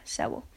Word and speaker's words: Sewell: Yeah, Sewell. That's how you say it.